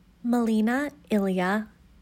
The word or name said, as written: Melina Elia